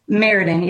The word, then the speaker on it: Meriden: And you're located in, is it pronounced Meriden? – Meriden, yeah.